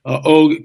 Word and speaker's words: Og: After the flood, we have Og, as Doug Hamp likes to call him, which is probably the correct pronunciation, Og, king of Bashan, at about 13 to 15 feet.